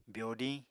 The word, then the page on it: byodiñ: apatani